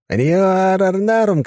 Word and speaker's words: Риорарнарум: «Риорарнарум, красавица!» Тело Кости сложилось в изящном поклоне. «К вашим услугам! Ударение на все «а»! Прошу не путать!» «Думаю, с его помощью мы сможем решить вашу проблему.